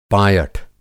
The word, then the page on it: पायठ: Hindi